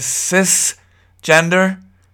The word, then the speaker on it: cisgender: Now, we have another word, and I'm not sure the pronunciation is cisgender. Is that correct? Cis, C-I-S, or cis, but I've heard it called cis.